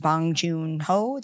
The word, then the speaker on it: Bong Joon-ho: There's a lot of depth to this film, and I think that Bong Joon-ho, did I say that right? – You did.